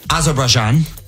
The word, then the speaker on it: Azerbaijan: Baku, Azerbaijanian. – Azerbaijan. Baku, Azerbaijan.